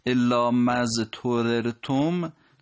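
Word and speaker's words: إلا ما اضطررتم: خب الا مضطررتم دو تا ره پشت سر هم یه مقداری تلفظ رو مشکل میکنه، حرف زاد ساکن و تای دستدارم به این شکل هم قرار گرفتن، یه مقدار تلفظش دقیقتر باید باشه اونایی که میخوان فصیحتر و دقیقتر بخونن.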